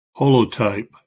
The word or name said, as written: holotype